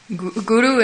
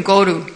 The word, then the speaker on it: guru: Guru and Goru. Yes, a little difference in pronunciation. One is cow, one is guru.